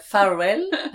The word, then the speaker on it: Farvel: c'est farvel, ça veut dire adieu tout simplement en oh, danois. D'accord. – Donc c'est pas le cap Farvel.